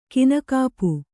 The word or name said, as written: ಕೈನೆಗಪು